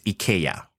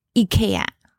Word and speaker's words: IKEA: And the correct pronunciation from the founder is IKEA. – IKEA. – Yeah. – Oh, it's IKEA. Should be pronounced in the Swedish way.